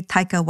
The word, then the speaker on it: Taika: It's very Taika Waititi-ish. – Like Tiger, but with a K, and instead of the ER at the end, you couldn't put an A, so it's like Taika. It's actually T-A-I-K-A, so you'd add an A between the T and the I in Tiger, so it's like Taika.